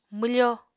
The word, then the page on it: mwfo: Apatani